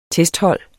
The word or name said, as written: testhold